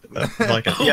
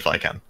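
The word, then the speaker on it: Vican: is that how it's pronounced? – I don't know. – I thought it was Vikan. – Oh, right. Vikan. Yeah, I always said Vikan.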